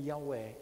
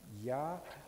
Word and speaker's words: Yahweh: The Hebrew name Yahweh is so written and in such a way has to be pronounced that you can only do it if you breathe in Yahweh. Try that. Yahweh. Yahweh. This is the only way that you can pronounce God's name correctly in the Hebrew language, because God is spirit.